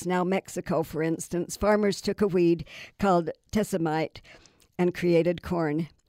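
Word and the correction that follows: teosinte: I mispronounced that. It's teosite, since tiny ears contain only about a dozen kernels.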